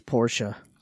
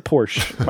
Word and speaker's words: Porsche: It's Portia. – I would say Portia. – I said Portia. – You'd be wrong. – Could be. It's Porsche. You pronounce it Porsche.